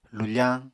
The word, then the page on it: lufañ: Apatani